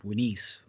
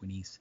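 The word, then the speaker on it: Unas: And behind that, a little ways away, you have the pyramid of Winis, or better known as Unis, but his name is actually pronounced Winis.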